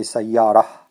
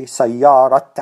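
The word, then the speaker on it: sayyara: If you're going to stop at the ta marbuta, you say sayara. But if you're continuing, you say sayarat Ali, for example.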